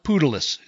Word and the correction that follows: Patelis: is that right? – Patelis. – No, I know. Patelis.